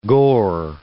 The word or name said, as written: gore